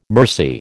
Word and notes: mercy: American English

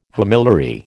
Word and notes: homiliary: American English

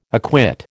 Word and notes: acquit: American English

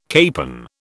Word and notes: capon: British English

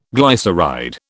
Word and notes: glyceride: British English

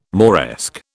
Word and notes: Moresque: British English

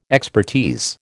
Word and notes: expertise: American English

